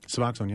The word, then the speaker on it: Suboxone: Question from a caller, Tim, on Suboxone. Did I say that right? – Suboxone, yeah.